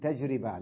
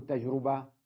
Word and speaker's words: تجربة: بعضهم يلفظها تجارب بالضم، خطأ، تجارب، ونقول تجربة لا نقول تجربة، والناس كلها تنطقها، غالبية الناس تجربة، تجارب، خطأ، تجارب وتجربة هكذا.